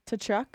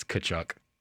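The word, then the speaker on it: Tkachuk: To Kachuk? I don't know. – You were right, but the T is silent, so it's Kachuk.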